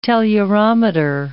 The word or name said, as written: tellurometer